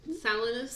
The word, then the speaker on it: Salinas: But her young life, she was born in Salinas, California. I don't know if I said that right. Sorry. – Salinas. Salinas. – Salinas. – Salinas. – Salinas, California. She is the oldest of four kids.